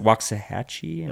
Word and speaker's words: Waxahachie: Was it Waxahachie? – Oh, you said it right.